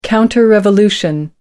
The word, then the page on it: counter revolution: American English